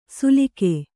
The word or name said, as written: ಸುಲಿಕೆ